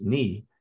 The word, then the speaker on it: genu: And at its anterior end is the genu, pronounced knee, mm-hmm.